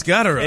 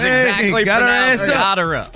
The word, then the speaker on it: Gotterup: That is exactly the pronunciation. – It's Gotterup. – It's Gotterup. – Is it really? – I just checked. Yes. It is Gotterup.